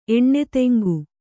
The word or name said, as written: ಎಣ್ಣೆತೆಂಗು